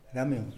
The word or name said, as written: gamion